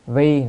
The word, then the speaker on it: vi: Ở đây đọc là vi chứ không đọc vị. Chữ này có hai âm, đọc vi, vị. Nếu đọc vị là vì, nhưng mà đây phải đọc là vi.